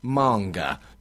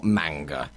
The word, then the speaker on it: manga: I've got Ben Newlin, who says, when I was about 15 or 16, he was condescendingly told by a clerk that it's pronounced manga, not manga.